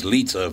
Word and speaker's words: Lizza: Is his name Ryan Lizza or Lizza? L-I-Z-Z-A. It should be pronounced Lizza if it's Italian, right? Oh, L-A's over on his phone over here. What a professional. Would you pronounce L-I-Z-Z-A? It's Lizza, is it not? – Lizza, yes.